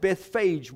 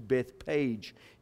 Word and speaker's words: Bethphage: I pronounce it Bethphage, by the way, because the Greek text says Betphago. So the F sound is in the Greek text. That's why I say Bethphage as opposed to Bethpage, if you are used to that.